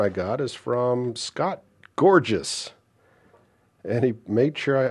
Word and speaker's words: Gorges: It's spelled G-O-R-G-E-S, and I probably would have just said Gorgeous, but he wrote me back and said, no, that's gorgeous. Don't laugh. It's gorgeous.